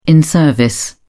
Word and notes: in service: British English

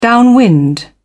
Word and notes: downwind: British English

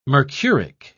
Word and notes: mercuric: British English

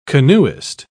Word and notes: canoeist: British English

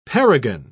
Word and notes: perigon: British English

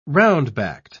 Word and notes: round-backed: British English

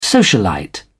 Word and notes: socialite: British English

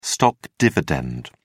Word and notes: stock dividend: British English

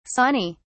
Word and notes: sawney: British English

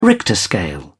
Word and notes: Richter scale: British English

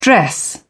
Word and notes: dress down: British English

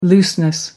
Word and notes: looseness: American English

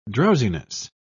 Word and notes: drowsiness: British English

